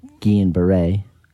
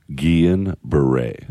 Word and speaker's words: Guillain-Barré: They found out that after about 4 days, they came back and said I had this rare autoimmune syndrome called Guillain-Barré. – Guillain-Barré. Yeah. How do you spell that? – G-U-I-L-L-A-I-N.